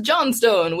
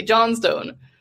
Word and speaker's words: Johnstone: my middle name is Lynn, L-Y-N-N. And my last name is Johnstone, which is like Johnson, only with flair. – There you go. – Spelled phonetically, Johnstone.